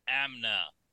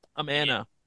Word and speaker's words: Amana: And Amna. – Amana.